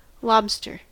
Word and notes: lobster: American English

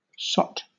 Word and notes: sot: British English

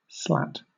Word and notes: slat: British English

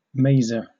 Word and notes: maser: British English

